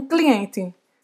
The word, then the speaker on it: client: Se eu quiser falar que eu tenho um rendezvous, né, um horário marcado com um cliente, como é que eu vou dizer isso? – J'ai rendez-vous avec un client. J'ai rendez-vous avec un client. O T, no final, não se pronuncia.